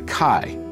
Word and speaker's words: chi: The center of the X, or as it's pronounced in Greek, the chi, as in Alpha Chi Omega.